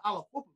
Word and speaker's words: Aliquippa: Aliquippa?" I said, "Yeah, why?" Yeah. I said, "No, Aliquippa, man.